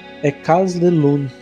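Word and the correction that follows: Écailles de Lune: Falei certo, Lucas? – É, segundo a minha amiga, a pronúncia é, é Kaidelu. – Kaidelu, ok.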